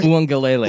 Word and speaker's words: Uiagalelei: Am I saying that right? – Uiagalelei. – Uiagalelei. Dang it. – Just say DJU. – No, I'm going to learn how to say his name. You know, yeah, one more time. – DJ Uiagalelei.